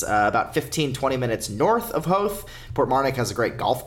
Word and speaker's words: Howth: Howth.